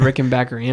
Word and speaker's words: Rickenbacker: I went back and forth. I said Rickenbacker and Rickenbacker, so either one.